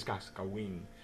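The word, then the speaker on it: Cascahuín: I think it's Cascahuin, with the H is silent.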